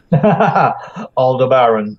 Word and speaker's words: Aldebaran: And another thing I greatly enjoy in listening to you is I learn how to pronounce things like Aldebaran correctly. – Aldebaran, thank you.